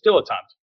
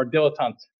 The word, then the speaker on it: dilettante: I mean, and there's several pronunciations for it. But yeah, it's dilettante or dilettante. It's a French origin, right?